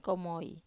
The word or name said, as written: komoi